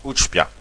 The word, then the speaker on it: Utchpia: Ça se prononce Utspia. C'est de la langue d'Oïle. – Ah, pouvez-vous nous appeler le mot? – Alors, U-T-C-H-P-I-A.